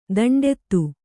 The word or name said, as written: ದಂಡಿತ